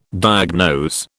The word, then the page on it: diagnose: British English